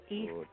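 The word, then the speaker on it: Yves: Well, the name is pronounced Eve. It's Eve. – Oh, Eve. – So that's Y-V-S. That stands for Eve, basically.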